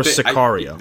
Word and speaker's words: Sicario: is Cicero, Day of the Soldado. – Oh, Scario. Scario? – Cesario. S-I-C-A-R. Or Sicario. – That's how they pronounce it.